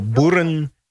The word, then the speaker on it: Boerenjongens: Buranyangas is spelled B-O-E-R-E-N. J-O-N-G-E-N-S. So Buranyangas. – M-O-U-S-E.